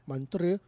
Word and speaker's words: Montreux: But that's how they said it, so that's what it sounds like. Montreux.